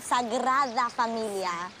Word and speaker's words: Sagrada Familia: When you learn how to pronounce it, Sagrada Familia.